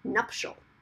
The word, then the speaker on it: nuptial: why do we say it with an extra vowel sound in there when right. there is no extra vowel sound? And then I looked it up, and while it said that people do pronounce it that way, that the actual pronunciation is supposed to be nuptial.